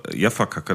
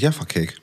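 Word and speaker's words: Jaffa Cake: also zur Erklärung für alle Hörer, äh, Nils und Dennis hauen sich hier gerade wir wissen nicht genau, wie es ausgesprochen wird. Wir haben immer Jaffa-Kacke dazu gesagt. Das ist der Jaffa-Cake.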